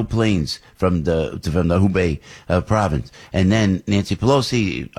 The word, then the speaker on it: Hubei: What's the Wabe? Is that the way you pronounce it, Gordon? The Wabe province? Is that where all this is happening? – Hubei. – Tell me again? – Hubei. – Hubei, right.